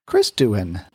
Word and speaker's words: Doohan: Yes, James Doohan's son. Chris Doohan. I'm hoping we're pronouncing his last name right. It's not Doohan, it's Doohan.